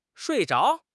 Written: shui4 zhao2

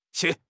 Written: 屑

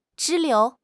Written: 支流

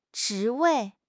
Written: 职位

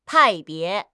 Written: 派别

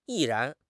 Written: 毅然

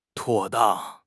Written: 妥当

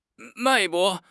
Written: 脉搏

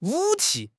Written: 武器

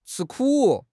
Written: 吃苦

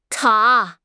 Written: 塔

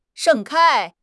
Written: 盛开

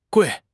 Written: gui4

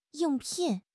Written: ying4 pin4

